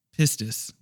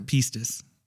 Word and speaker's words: pistis: The Greek word pistis. Or pistis. – Either, so I don't know. – Either, okay, yes. Yeah, so. Like the pronunciation is kind of lost by now.